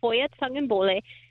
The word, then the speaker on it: Feuerzangenbowle: So that's how you say it. It's Foya Tsangambole, and it basically means fire punch.